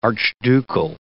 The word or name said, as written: archducal